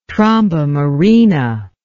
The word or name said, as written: tromba marina